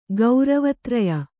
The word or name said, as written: ಗೌರವತ್ರಯ